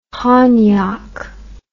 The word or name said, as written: honyock